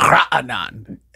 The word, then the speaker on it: Raanan: And it's honestly, it's either because it's a Hebrew name. And in Hebrew, they have this guttural sound, Raanan. And they don't have that in English. So you can't make, I can't even pronounce my name.